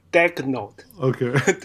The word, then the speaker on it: Daigneault: 我听个很多 podcast 都是念 Daigneault，OK，对，Mark Daigneault，它是法文嘛？